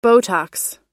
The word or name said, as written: Botox